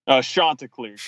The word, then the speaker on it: Chanticleers: Chanticleers.